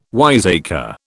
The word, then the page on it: wiseacre: British English